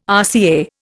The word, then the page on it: RCA: Indian